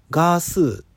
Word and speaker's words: ガースー: ご自身の発音を聞くとガースですっていうふうにおっしゃってて、うん、あの Hulu とか、えー、 MeToo とかそういうのと同じ発音でしたね。